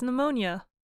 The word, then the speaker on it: pneumonia: Another word commonly misspelled is pneumonia. Maine, Alabama, Michigan, and Washington all have trouble with that one. – Well, it is spelled P-N-E-U-M-O-N-I-A. It is hard because the P is silent. Plus, it is easy to mix up the order of N-E-U.